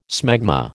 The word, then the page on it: smegma: American English